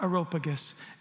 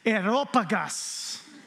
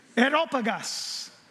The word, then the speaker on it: Areopagus: So, I learned to say this word Areopagus when I was in Greece, and I'm going to teach you how to say it. because it's not Areopagus, it's Areopagus. Okay? Areopagus.